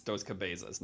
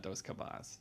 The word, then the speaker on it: Dos Cabezas: I want to interrupt because it's Dos Cabezas, not Dos Cabas.